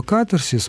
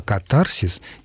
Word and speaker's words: катарсис: А вот относительно слова катарсис указано, что ударение должно быть только таким, а катарсис неправильно.